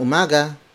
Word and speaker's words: umaga: It's Magandang umaga.